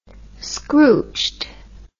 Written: scrooched

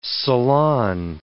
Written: salon

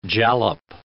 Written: jalap

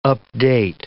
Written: update

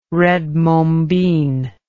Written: red mombin

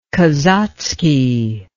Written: kazatsky

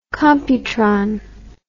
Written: computron

